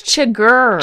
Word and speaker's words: Chigurh: And Chigurh is always like flipping his coin. And I'm saying his name wrong, am I now? – It's Chigurh. How Chigurh. – hard is that? – I am sorry.